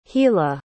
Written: gila

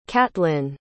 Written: catlin

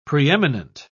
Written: preeminent

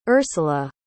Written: ursula

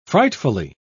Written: frightfully

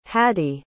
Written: hadi

